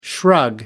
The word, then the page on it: shrug: British English